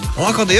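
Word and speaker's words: 十八番: あなたのカラオケ 18 番リクエスト、 18 番と書いておはこと読む。 – いいですね、おはこと読むルフィみたいな感じですね。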